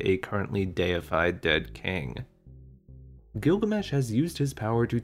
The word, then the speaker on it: Gilgamesh: Well, in Sumerian, his name possibly would have been pronounced Bilgamesh. Gilgamesh is actually the Akkadian pronunciation of the name.